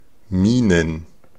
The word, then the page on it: Mienen: /ˈmiːnən/